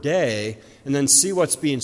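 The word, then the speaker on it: day (δεῖ): It's simply three letters, and to pronounce it in English is to simply say day. So the Greek word pronounced day is a very short but a very powerful and a very important word.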